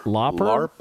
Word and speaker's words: Lauper: You'd rather go Cindy Lauper than is it Lauper or how do you pronounce it? – Lauper.